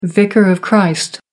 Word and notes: vicar of christ: American English

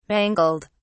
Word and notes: mangled: British English

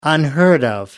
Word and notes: unheard of: American English

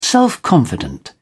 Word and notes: self-confident: British English

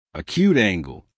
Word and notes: acute angle: American English

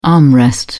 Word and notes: armrest: British English

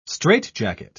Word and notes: strait-jacket: British English

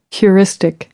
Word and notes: heuristic: American English

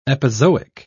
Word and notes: epizoic: British English

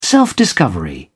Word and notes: self-discovery: British English

